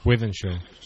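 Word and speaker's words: Wythenshawe: Wythenshaw. – Wythenshaw.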